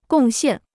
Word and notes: 贡献: gòng xiàn

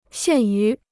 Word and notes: 限于: xiàn yú

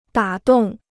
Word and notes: 打动: dǎ dòng

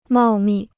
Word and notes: 茂密: mào mì

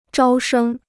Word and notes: 招生: zhāo shēng

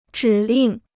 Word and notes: 指令: zhǐ lìng

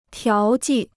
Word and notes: 调剂: tiáo jì

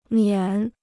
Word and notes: 免: miǎn

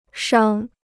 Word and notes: 伤: shāng

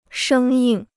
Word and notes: 生硬: shēng yìng

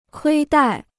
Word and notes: 亏待: kuī dài

